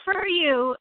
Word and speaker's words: FurYu: No, we're not. So it's FurYu, and if you want to see the horrors of this, just go to MLP merch, go under plushies, and find FurYu.